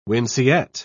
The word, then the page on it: winceyette: British English